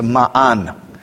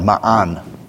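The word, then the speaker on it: ma'an (מן): But in Hebrew, it's ma'an. It ends with a nun, which is an N sound, ma'an.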